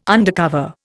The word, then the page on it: undercover: Indian